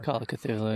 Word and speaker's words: Cthulhu: Call of Cth- oh, how do you say that? C- Cthulhu. Cthulhu. Yes, I'm sorry. – Call of Cthulhu.